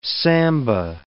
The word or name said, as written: samba